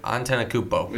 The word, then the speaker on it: Antetokounmpo: Antetokounmpo. – Yeah, Antetokounmpo.